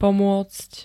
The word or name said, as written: pomôcť